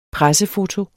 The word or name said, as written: pressefoto